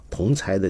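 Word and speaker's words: 同侪: 那么比如说夕阳白秋念，你说道长你好，不好意思，指出一个读音啊，同才的第二个字念才，和柴火的柴同音。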